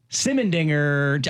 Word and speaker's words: Simendinger: Gracie Simendinger.